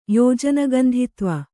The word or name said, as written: ಯೋಜನಗಂಧಿತ್ವ